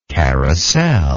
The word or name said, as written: carrousel